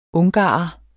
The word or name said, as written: ungarer